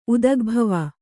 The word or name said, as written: ಉದಗ್ಭವ